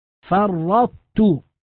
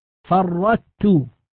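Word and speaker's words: فرّطت: ومن ذلك القسم ما نذكره الآن في الإدغام في المثلين والمتقاربين والمتجانسين، فمثلا كلمة فرطت، نلاحظ أنها عند النطق بها لابد أن نبقي صفة الطاء وهي الاستعلاء، فننطق بتاء مشددة ويبقى أثر الاستعلاء في الطاء، فرطت، هذا إدغام ناقص، لا يجوز أن نقول فرطت هكذا بتاء مشددة، هذا النطق خطأ.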